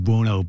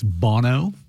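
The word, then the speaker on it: Bounou: That Moroccan goalkeeper, though, even though it's pronounced Buono, but it's on his back, it's Bono.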